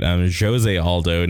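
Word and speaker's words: José Aldo: There's Alexander Volkanovsky is number 2, Brian Ortega number 3, Zoje. I fucking said his name wrong. It's getting late. Jose Aldo number 4, Frankie Edgar 5, and Zabit, Chan Sung Jung, Jeremy Siemens, and Josh Emmett.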